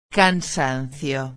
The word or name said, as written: cansancio